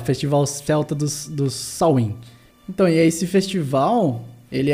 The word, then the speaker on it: Samhain: Na realidade, é, se escreve Samhain. Uhum. Já teve pronúncia que é Suwin. Eu procurei lá, tem uma discussão etimológica de uma galera de linguística, cara, tudo brigando. Mas sim, o, o, consenso até então é que é Samhain.